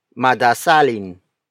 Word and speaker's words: madasalin: For example, madasalin. Madasalin. Madasalin. Madasalin is spelled as M-A-D-A-S-A-L-I-N.